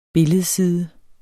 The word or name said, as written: billedside